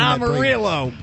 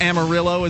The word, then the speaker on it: Amarillo: Amarillo. – God. – 1-800. – Albuquerque. – Is it Amarillo as well? – It is Amarillo. – No, it's not. It's Amarillo.